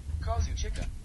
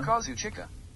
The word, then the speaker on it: Kazuchika: Kazuchika. – Oh, did you hear that? – One more time. – Kazuchika. – Next one. – Kazuchika.